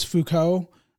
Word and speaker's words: Foucault: I think it was Foucault, if I'm pronouncing that yes. – right. – Yes, you are.